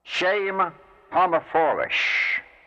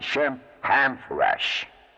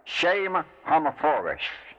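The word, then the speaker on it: Shem HaMephorash: This is one of the most awfully and commonly mispronounced Hebrew words of the Kabbalah. Those who should know better have often pronounced it Shem Hamphorash, which is vulgar and stupid.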